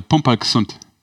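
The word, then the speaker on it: pumperlgesund: Wir wollen sie alle pumper gesund. – Ja. – Habe ich das gut ausgesprochen? – Das ist gut ausgesprochen.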